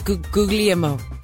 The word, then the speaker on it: Guglielmo: Guglio. – It's not Guglio, he's Guglielmo.